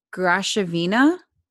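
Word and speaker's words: Graševina: The S with the thing sounds like a sh. Okay. So Grashovina.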